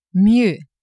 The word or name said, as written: mieux